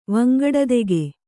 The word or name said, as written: ವಂಗಡದೆಗೆ